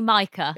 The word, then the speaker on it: Micah: And speaking of not being able to pronounce names, people tend to have a difficulty with Micah, whether it's Micah or Micah. – Oh, yeah, what is it? – It's definitely Micah. – Is it Micah? – It's Micah like striker and not Micah like streaker.